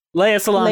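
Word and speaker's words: Lea Salonga: Leia Salonga. – Leia Salonga. – Leia Salonga.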